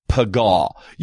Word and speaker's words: Paga: And then the Hebrew, and then towards the English, what I would call transliteration, it's P-A-G-A, Paga. You might want to just try to say that right now and wherever you're at and whatever language you speak. And let's just say together, Paga.